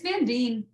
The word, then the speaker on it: Van Dien: It's Van Dien. – But... Van Dien, like James Dean, but with a big van in front of it.